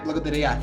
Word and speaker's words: Благодаря: Благодаря. Yeah. Did I say that right? Благодаря. – Yeah, perfect.